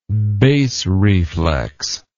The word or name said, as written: bass reflex